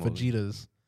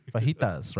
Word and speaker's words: fajitas: that's like saying fajitas. You know, that's not pronounced. – Yeah. – We don't say the J. – That's true. – Fajitas, right?